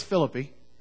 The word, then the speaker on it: Philippi: And by the way, it is Philippi. It's Thessaloniki. That is the way you pronounce those. It's not Philippi.